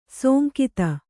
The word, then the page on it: ಸೋಂಕಿತ: sōnkita